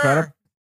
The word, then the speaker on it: Crudup: Crudup, is that how you say his name? Okay, yeah, Billy sure. – Crudup? – Yeah.